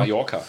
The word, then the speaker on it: Mallorca: Ja, Mallorca-Urlauber oder Mallorca, wie spricht man das aus? Mallorca, ne? – Mallorca.